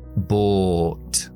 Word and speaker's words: bought: Empezamos con la temida terminación O-U-G-H-T. Hay cinco verbos comunes con esta terminación en inglés y la pronunciación es la O larga. Son muy importantes y muy difíciles de identificar en conversación si no dominas este sonido. Empezamos con comprar. Comprar es buy, en el pasado es bought. Bought. Pronunciación estadounidense, bought.